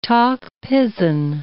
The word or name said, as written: Tok Pisin